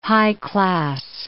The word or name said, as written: high-class